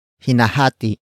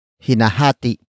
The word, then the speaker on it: hinahati: Hinahati na ang cake. Hinahati na ang cake. The first word is hinahati. Hinahati. Hinahati. Hinahati is spelled as H-I-N-A-H-A-T-I. Note the glottal stop at the end.